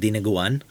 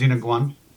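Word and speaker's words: dinuguan: Yeah, there's a Filipino dish called dinaguan. – It's actually pronounced dinaguan.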